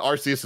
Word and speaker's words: Arceus: This is the thing where no matter what way we say it, people are going to say we're saying it wrong. But Arceus is the way I say it, and I've seen people I respect say it.